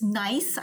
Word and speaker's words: Gneiss: It's spelled G-N-E-I-S-S. So it's nice, I think.